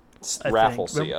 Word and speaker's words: Rafflesia: I guess for those of you desperately trying to Google what this flower looks like, it's Rafflesia. Rafflesia. – Ah, that's now you've found the pronunciation. – The classic musician. – Rafflesia. – Remember Sia? – Yeah, it's like Sia, but you know, sometimes you get music and sometimes you get diddly.